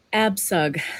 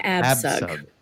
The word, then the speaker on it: Abcug: Absug. Cynthia Absug. Yeah, the C is like an S. – All right. So we're going to talk about the story of Cynthia Absug.